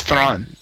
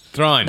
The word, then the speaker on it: Thrawn: It's pronounced Thrawn. – Thrawn.